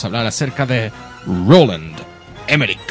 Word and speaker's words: Emmerich: Sí, Emmerich se deletrea E-M-M-E-R-I-C-H. Rich. – Pero las últimas sílabas son rich. – Como de rico.